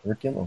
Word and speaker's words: Auriculum: Oh, Auriculum? – Auriculum. Wow, okay, there you go. That's way better way of saying it.